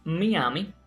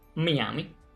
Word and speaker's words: Miami: El libro de estilo de la lengua española nos recuerda que el topónimo Miami se pronuncia así como lo acabo de hacer, Miami. Y no es apropiada la pronunciación Miami, ni menos Miami.